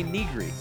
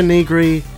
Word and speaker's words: Negri: No, I always say Negri, and I just say Negri. I don't know. – Have I been pronouncing it wrong? – Maybe. Martin, what do we have a confirmation on this? – Sounds like you're saying a completely different word when you're saying Nigiri. – There we go. – Oh shit. – So who's saying? So I'm saying it wrong.